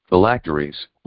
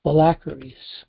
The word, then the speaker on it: phylacteries: Phylacteries. – Phylacteries.